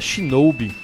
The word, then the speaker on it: Shinobi: Não é Shinobi não, na verdade a pronúncia é Shinobi.